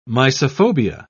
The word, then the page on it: mysophobia: British English